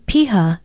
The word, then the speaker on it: piha: Today's word is piha. It is spelled P-I-H-A.